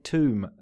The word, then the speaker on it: tomb: Notice that I said tomb, though. It's got a silent B, that word, Dan.